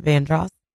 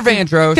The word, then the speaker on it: Vandross: Luther Vandross. – Because you said, I said it's Ross if you take off the Van, and you said, no, how do you say like, gross? – It's like gross, right?